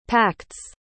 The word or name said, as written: pact's